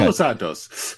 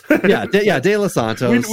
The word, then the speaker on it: De Los Santos: And then the only other one that I thought was kind of interesting from the Rule 5 is, I'm going to pronounce it incorrectly, Davison De Los Santos. Say that properly, would you? You know, well, I think you're close enough. It's okay. De Los Santos.